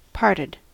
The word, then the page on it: parted: American English